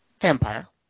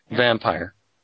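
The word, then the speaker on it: Vampyr: But number three, um, how would you say this word? I'm just asking you as Genghis. Right. G A M P Y R. – Vampire. – All right. Vampire. That's right.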